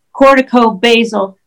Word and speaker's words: Corticobasal: Corticobasal, basal like the herb, degeneration is the proper name, I think.